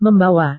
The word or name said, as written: membawa